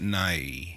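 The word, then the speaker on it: Nighy: How do you pronounce the last name? – I've always pronounced it Nye.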